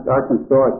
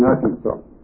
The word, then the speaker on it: Arkansas: This, please note, is not pronounced Arkansas except in Arkansas.